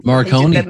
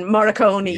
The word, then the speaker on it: Morricone: And I can never say his name, Ennio Morricone. – Morricone.